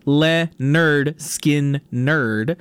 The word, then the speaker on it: Lynyrd Skynyrd: Right before that was, of course, Free Bird by Lynyrd Skynyrd off of their album pronounced Le-Nerd Skin Nerd.